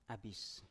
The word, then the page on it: abis: Biak